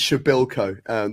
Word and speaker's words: Shabilko: But yeah, the spelling looks tough, but it's Shabilko, the way it's pronounced.